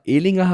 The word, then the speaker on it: Ehlinger: So wie Ellinger Ehlinger heißt. Und ich habe noch was gelernt, das wusste ich auch nicht. Ähm, Mullins, der Backup-Quarterback, heißt wohl Mullins. Es gibt immer wieder Neues, was man lernt in Aussprache.